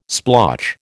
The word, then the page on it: splotch: American English